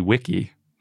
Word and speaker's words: Vici: Another fun one is in the original Latin, Veni Vidi Vici was probably pronounced Veni Vidi Wiki. – Oh, that's different. – Because not only is the C a K, but V's were W's. So all that is to say,